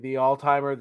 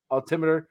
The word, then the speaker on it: altimeter: And then the altimer, altimeter. – Altimeter.